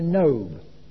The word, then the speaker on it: Nob: It reveals that one day David came to Nob. I suppose that's how you would pronounce it. N-O-B.